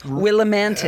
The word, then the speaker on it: Willimantic: It's not Willimantic. it's, it's spelled with a T at the end, Willimantic. Right. That's right. – it's that's, romantic Willimantic.